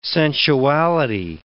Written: sensuality

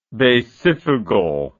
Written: basifugal

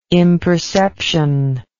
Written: imperception

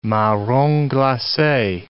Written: marrons glaces

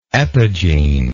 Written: epigene

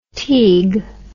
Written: Teague

